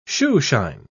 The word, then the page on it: shoeshine: British English